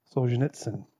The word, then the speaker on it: Solzhenitsyn: I practice pronouncing his name, and it's quite a tough one to get, Solzhenitsyn.